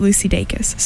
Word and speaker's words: Dacus: I recently went to her concert and I've never heard her name said out loud before, and she said it and it's Lucy Dacus, so I have to correct myself. I've been saying it wrong for like a year and a half.